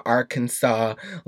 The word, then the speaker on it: Arkansas: I called it Arkansas, porque that's how you say it phonetically. And somebody was like, it's Arkansas. And that's, pero tiene dos S's? Wait, Arkansas. Pero no, Arkansas.